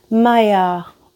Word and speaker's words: Maillard: This is a fancy French name that a lot of times people don't know how to pronounce, but I've been told that it's the Maillard reaction.